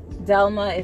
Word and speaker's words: Delma: You guys can actually find me on YouTube under Shirley Delma. That's my name, S-H-E-R-L-E-Y. Delma is spelled D-E-L-M-A.